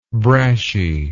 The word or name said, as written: brashiness